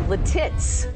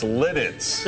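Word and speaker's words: Lititz: Letits. – What? No, that's not. – Letits. – That's not right. – It's spelled. – No, it's Letits.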